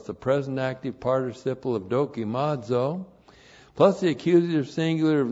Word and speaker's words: dokimazo: And then we have this neat verb, dokimazo. Dokimazo. D-O-K-I-M-A-Z, long O. Dokimazo.